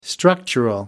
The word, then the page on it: structural: American English